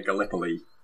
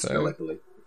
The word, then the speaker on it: Gallipoli: Yeah, that's the Gallipoli campaign. I think I pronounced that correctly. It's been a while since I've had to pronounce Gallipoli. – I think that's right, yeah.